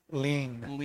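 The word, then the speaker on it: Ligne: It's is it pronounced Line or Ligny? – Lean. – Lean. It's a Lean 2. – It's French.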